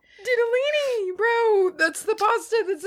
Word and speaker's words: ditalini: Didalini.